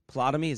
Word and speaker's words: Ptolemy: P-T-O-L-E-M-Y. Anybody know how to say his name? Ptolemy? Ptolemy, is that it? – Ptolemy. – Yeah, the P is silent, isn't it?